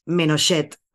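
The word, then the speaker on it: Ménochet: Que por cierto, ni Denis Menochet, ahora lo he dicho bien, ni Marina Foix no sabían nada de español.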